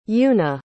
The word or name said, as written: euna